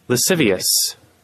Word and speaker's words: lascivious: How do you say this word? L-A-S-C-I-V-I-O-U-S? – We'll allow Google to pronounce it. Ready? – Lascivious. – Lascivious. Thank you.